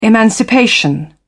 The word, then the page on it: emancipation: British English